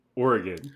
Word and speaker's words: Oregon: Oregon.